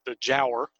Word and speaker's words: Giaour: But it's, and I think I'm pronouncing this right, my understanding is, from what I looked up, it is pronounced the Giaour. Giaour is spelled Giaour.